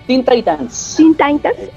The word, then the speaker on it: Teen Titans: Luego viene Teen Titans, correcta la pronunciación. – Teen Titans, exacto, en el 2003 al 2006, una serie que viene como a refrescar, ¿no?